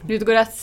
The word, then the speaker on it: Ludogorets: Ludogorets Sånn, jeg vet ikke om det er sånn man uttaler det engang. – Tror det. Ludogorets i 2014.